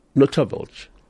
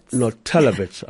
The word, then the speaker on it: Notelovitz: On that note, let me have a warm welcome to Avril Notelovitz. – Notelovitz, I beg your pardon.